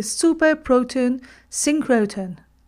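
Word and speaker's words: Super Proton Synchrotron: called the superproton synchrotron. I have not mispronounced, that's how it's called.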